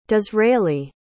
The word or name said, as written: disraeli